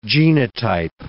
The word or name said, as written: genotype